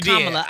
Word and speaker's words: Kamala: You Kamala.